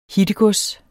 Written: hittegods

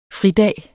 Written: fridag

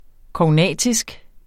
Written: KOGNATISKE

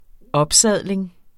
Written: opsadling

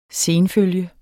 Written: sen

